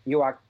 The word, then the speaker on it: Newark: Now, how do we pronounce it? Newark. – Newark. Yes, we do. Newark. – Newark. – All right. – Newark. Newark, New Jersey. – That's it. Well done.